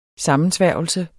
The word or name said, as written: sammensværgelse